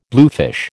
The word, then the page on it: bluefish: American English